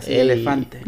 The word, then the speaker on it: elefante: So obviously we want to sort of address the elefante in the room. Is that how you say it in Spanish? – Correctamente. Así elefante.